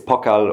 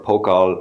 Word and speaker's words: Pokall: Yes, so it's it's Pokall or Pokall or Pokall, or, you know, there's so many different pronunciations. You know, I've heard them all now growing up in Ireland, but it's a German name.